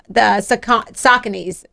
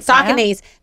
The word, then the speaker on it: Saucony: And I've been wearing the same shoe, the Saucony's. Is that how I'm saying that right? – Yep. – Saucony's.